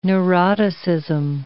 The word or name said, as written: neuroticism